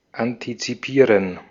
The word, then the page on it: anticiper: France; /ɑ̃.ti.si.pe/